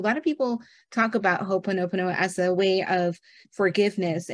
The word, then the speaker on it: ho'oponopono: yeah you mentioned ho'oponopono, is that, did I say that correctly? – Mm-hmm. – Mm-hmm. Okay. I wanted to make sure I said it correctly.